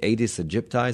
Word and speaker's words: Aedes aegypti: And in January, health officials were reporting that only one type of mosquito, I'm going to say this incorrectly, the Aedes aegypti, is that how you say it? – Mm-hmm. Very good.